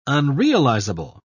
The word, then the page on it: unrealizable: British English